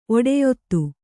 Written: ಒಡೆಯೊತ್ತು

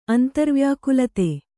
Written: ಅಂತರ್ವ್ಯಾಕುಲತೆ

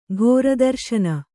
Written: ಘೋರದರ್ಶನ

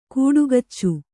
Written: ಕಾಡ್ಗಿಚ್ಚು

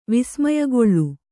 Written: ವಿಸ್ಮಯಗೊಳ್ಳು

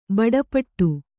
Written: ಬಡಪಟ್ಟು

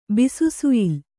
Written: ಬಿಸುಸುಯ್ಲು

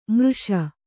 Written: ಮಾರ್ಷ

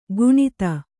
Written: ಗುಣಿತ

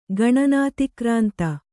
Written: ಗಣನಾತಿಕ್ರಾಂತ

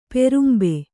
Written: ಪಾರಂಬೆ

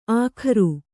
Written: ಅಗರು

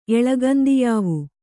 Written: ಎಳಗಂದಿಯಾವು

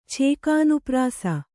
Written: ಛೇಕಾನುಪ್ರಾಸ

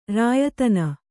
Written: ರಾಯತನ

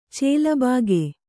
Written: ಚೆಲಬಾಗೆ

